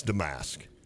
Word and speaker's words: Domask: Brad Underwood gives, I think, five different pronunciations for Marcus Damask. Domask. – Domask. – Damask. – Damask. Yeah, it's Damask.